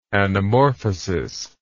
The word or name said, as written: anamorphosis